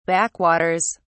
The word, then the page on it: backwaters: British English